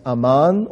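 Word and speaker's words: aman (אמן): And that would be pronounced aman, or we might even say amen.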